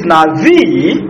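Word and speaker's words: Navi (נביא): In Hebrew it is navi. Yeah, in writing it's N-A-B-I, so you can be tempted to call it Nabi, but it's Navi, the B is V, pronounced as V. Navi.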